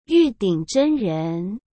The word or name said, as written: yudingzhenren